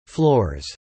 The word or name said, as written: floors